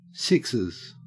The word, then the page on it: sixes: Australian English